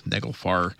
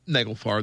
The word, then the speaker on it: Naglfar: And Ship of Nails, Naglfar. This is going to be Naglfar. The song is, let's see, what's this? Unleash Hell. So here is Naglfar. This is Wreckage. This is WREK Atlanta. Rise up! Or that was Neglfar, N-A-G-L-F-A-R is how you spell that, by the way.